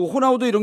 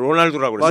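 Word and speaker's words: Ronaldo: 호나우도 맨 처음에 로날, 로날두라고 그랬어요. 로날두를 읽었었잖아요. 로날두. 네, 네, 알은 발음이 안 되는 건데.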